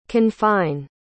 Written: confine

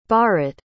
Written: bharat

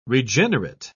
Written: regenerate

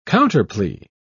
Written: counterplea

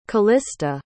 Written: kalista

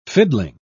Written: fiddling

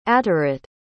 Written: ateret

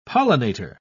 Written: pollinator

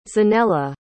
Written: Zanella

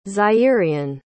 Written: Zairian

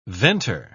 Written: venter

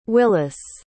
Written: Willis

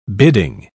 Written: bidding

